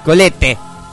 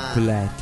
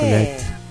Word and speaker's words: Colette: Es Colette. – Muy bien. Colette. – La E final es como que amaga aparecer, pero no se nombra. – Es Colette. Semimuda.